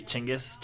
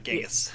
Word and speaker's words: Chinggis: Excuse me, it's actually Chinggis. Chinggis, it's not Genghis.